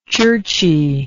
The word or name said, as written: churchiness